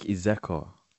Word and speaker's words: Izekor: It's Isaac Izekor.